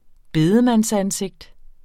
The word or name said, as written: bedemandsansigt